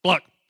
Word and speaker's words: Balak: Balak. I looked it up on the internet, how to pronounce it. And the woman on the internet said, Bluck. I'm like, that doesn't help me at all. Bluck. Sound like a chicken.